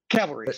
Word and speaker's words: cavalry: So I'm, you know, I wouldn't trust that the Calvary is coming. – Trusting the Calvary is coming. – Yeah. Cavalry. Sorry. I mispronounced it.